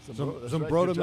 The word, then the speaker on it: Zumbrota: is it Zumbrota? – Yeah, Zumbrota, Minnesota.